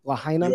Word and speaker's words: Lahaina: Am I pronouncing that correctly? Lahaina? – You are. You are.